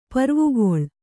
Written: ಪೂರ್ವಗೌಳ

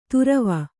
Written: ತೊರವಿ

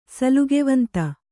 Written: ಸಲ್ಗೆವಂತ

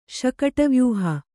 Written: ಶಕಟವ್ಯೂಹ

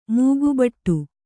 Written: ಮೂಗುಬಟ್ಟು

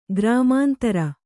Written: ಗ್ರಾಮಾಂತರ

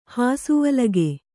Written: ಹಾಸುವಲಗೆ

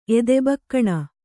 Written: ಎದೆಬಕ್ಕಣ

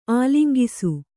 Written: ಆಲಂಗಿಸು

